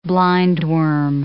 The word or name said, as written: blindworm